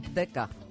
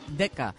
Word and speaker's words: Ditka: Ditka. Ditka.